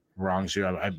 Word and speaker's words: Rong Zhu: Rong Zhu tried to take him down, and when he got him down, he swept Rong Zhu. I apologize if I'm saying his name incorrectly. – Perfect, saying it perfect.